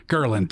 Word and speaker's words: ghrelin: But at the same time, as challenging as it is, there's a chemical, and I think it's pronounced ghrelin or ghrelin, something like that. – Ghrelin. – Thank you. Mm-hmm. Your normal stomachs produce. – Ghrelin. That's what it is.